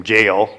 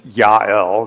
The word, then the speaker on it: Yael: Often we pronounce the name Jael, but it's Yael. It's definitely the Yod in the Hebrew.